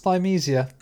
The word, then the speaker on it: Thymesia: It's actually pronounced Thymesia.